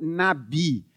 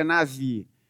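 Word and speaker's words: navi (נביא): E existe uma discussão se a pronúncia correta dessa palavra seria navi ou nabi. O meu professor de antigo texto, de, de hebraico no seminário dizia navi. Então eu vou na linha do meu mestre.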